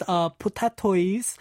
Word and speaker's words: Potatoi: So this is Putatoi's. It's spelled P-O-T-A-T-O-I. So like potato, yeah, with an potato. I at the end.